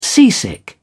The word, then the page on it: seasick: British English